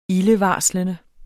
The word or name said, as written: ildevarslende